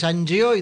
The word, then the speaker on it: Angió: Y bueno, a mí también, en mi apellido es Angio y todos me dicen Angio, ¿viste? Angio, le tengo Angio. Que decir, va el acento en la O y te, le decís va el acento en el otro y dicen, ah, bueno, Angio, anda a cagar, ya está.